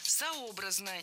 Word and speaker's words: сообразно: А конфессиональные праздники… Сообразно, неверно. Правильное ударение в этом наречии – сообразно. Ударяем третий слог.